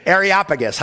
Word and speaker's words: Areopagus: Areopagus, how do you say it? Well, you say it just like Snuffleupagus. If you ever watch Sesame Street, you can say this name, Areopagus. It's just like that.